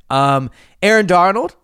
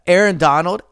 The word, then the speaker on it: Aaron Donald: Aaron Donald, I don't know why I mispronounced that. Aaron Donald, out of retirement, official on Sunday.